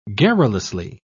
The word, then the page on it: garrulously: British English